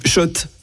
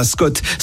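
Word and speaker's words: Schott: Ça, oui, Shot. Et pas Scott, ça fait des années qu'on prononce mal la marque.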